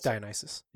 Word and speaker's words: Dionysus: Like, I think Dionysus, like, when you... Because I have him, like... All right, is that how you say it? I can't remember. – Yeah, it's Dionysus.